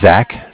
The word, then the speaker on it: Zach: Yeah, my name is Zach, spelled Z-A-C-H.